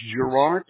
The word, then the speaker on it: Gerard: My name is Gerard.